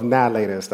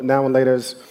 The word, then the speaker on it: Now and Laters: Nalaters. That's how you pronounce it. Nalaters. We came home with like 2 pillowcases full of Now and Laters.